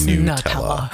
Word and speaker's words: Nutella: One option is the Nutella, or should I say Nutella. Properly pronounced, it's Nutella. Nutella.